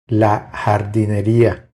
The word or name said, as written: La jardineria